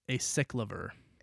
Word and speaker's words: acyclovir: I think it's acyclovir. – Acyclovir is what I was going to say, too.